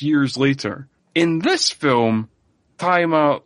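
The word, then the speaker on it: Tzi Ma: Who is played by, I think his name is pronounced Taima. It's spelled T-Z-I, and then his surname is just Emmy, but so I think it's Taima. It's how you pronounce that.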